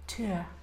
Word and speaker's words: tour: I remember at drama school we were told that was the correct RP pronunciation of tour. Tour. – Really? – Yeah. It's like it's the correct RP pronunciation. – Tour de force? – Two syllables. Tour.